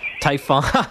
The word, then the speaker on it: Typhon: Typhon. You're not the first person to mispronounce it, Robin. I'll let you know. – Okay. – No, it's been called the tampon. It's been called typhoon. It's been called tofu. – Tofu. Yeah, that's actually. And the other thing is, I'm not Greek, so I'm only assuming that it's pronounced typhon.